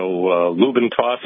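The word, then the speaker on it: LubeNToss: And then I found that when people were pronouncing it, they didn't enunciate the letter D. So it sounded like lube and toss. So I decided to go with the lube and toss. So Lubintoss is how you pronounce it, but it's spelled Lube N, the capital letter N, Toss with a capital N, rather than an ampersand sign.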